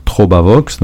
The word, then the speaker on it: Trobavox: Et puis aussi sur le site de l'éditeur, Trobavox, donc je dis comment ça s'écrit, hein, T-R-O-B-A, plus loin, Vox, V-O-X. Donc c'est Vox, c'est du latin, ça veut dire la voix en latin.